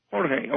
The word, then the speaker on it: Jorge: George Luis, that's spelled with a J, George Luis Borges wrote a book back in the 1930s called Ficciones.